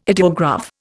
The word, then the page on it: ideograph: Indian